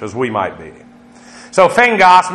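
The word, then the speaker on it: phengos (φέγγος): You got this unusual word, it's only used twice in the Greek, of phagos. And actually, in terms of Greek transliteration, it would be pronounced phengos. P-H-E-N-G-O-S would be the way you would pronounce it, phengos.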